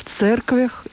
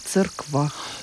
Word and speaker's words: церквях: В церквях или в церквах? – Тоже произносительные варианты, возможно, и то, и другое. Отчасти это связано с древним произнесением этого звука «ри», он был действительно мягким. Но сейчас у словари дают обе формы как одинаково возможные.